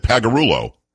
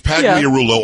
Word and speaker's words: Pagliarulo: Leaving out the LIA. It's like, it's Pagliarulo or Pagliarulo. No, no, he said Pagliarulo. – I mean, it is easier to say. – Wow. – I will say that because you don't have to throw in the... Pagliarulo. It's only four syllables when Pagliarulo is five.